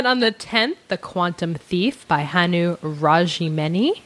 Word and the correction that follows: Rajaniemi: I hope I'm pronouncing that close. Rajaniemi, maybe? – Rajaniemi.